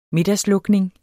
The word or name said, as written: middagslukning